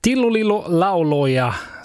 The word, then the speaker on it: Tillo lillo lauloja: Tillo lillo lauloja, så har jag lärt mig att det ska uttalas, och det betyder ungefär tralala sånger.